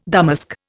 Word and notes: damask: Indian

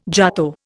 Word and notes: jato: Indian

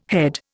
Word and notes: head: Indian

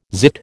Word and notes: zit: American English

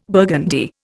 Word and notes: burgundy: Indian

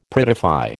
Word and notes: prettify: American English

